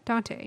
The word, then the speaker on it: Danyte: Yeah, I'm giving it to him because, a little bit more, I don't know how to pronounce his name, D-A-N-Y-T-E, Dante? – Dante? – Dante. – Mm-hmm. – It's a little bit different to spell it, but, so Dante was actually pretty phenomenal.